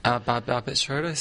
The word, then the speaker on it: Barbet Schroeder: But from memory, that was a Babrat Schroeder, am I pronouncing that right, directed it? – Barbet Schroeder sounds about right.